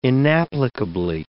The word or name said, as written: inapplicably